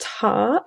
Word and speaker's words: Tár: And then finally, we have to talk about Tár.